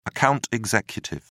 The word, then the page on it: account executive: British English